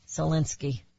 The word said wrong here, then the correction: Alinsky: Sal Alinsky. Always want to slide it together. Salinsky. Sal Alinsky.